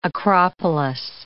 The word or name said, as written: acropolis